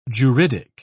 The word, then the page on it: juridic: British English